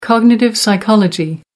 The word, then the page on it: cognitive psychology: American English